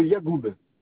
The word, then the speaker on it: Yagoub: Oui, c'est monsieur Yagoub. – Ça s'écrit Y-A. – Oui. – Y-A-G-O-U-B. – O-U-B.